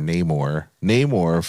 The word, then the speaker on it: Namor: which is Namor's or Namor for the comic book fans, but of course they pronounce it a little differently, Namor, for this film of Telokan.